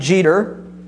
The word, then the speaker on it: Jetur: Etruria was from this name, we would pronounce it Jeter, I-E-T-U-R or J-E-T-U-R.